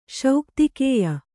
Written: ಶೌಕ್ತಿಕೇಯ

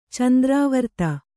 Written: ಚಂದ್ರಾವರ್ತ

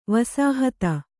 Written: ವಸಾಹತ

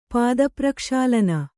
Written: ಪಾದಪ್ರಕ್ಷಾಳನ

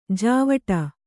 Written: ಜಾವಡಿ

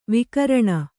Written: ವಿಕಿರಣ

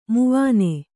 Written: ಮಾವಣ್ಣು

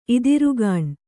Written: ಇದಿರುಗಾಣ್

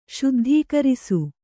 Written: ಶುದ್ಧೀಕರಿಸು

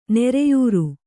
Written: ನೊರೆಯಾರು

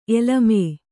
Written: ಎಲಮ

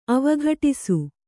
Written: ಅವಘಟಿಸು

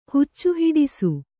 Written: ಹುಚ್ಚುಹಿಡಿಸು

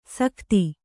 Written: ಸಕತಿ

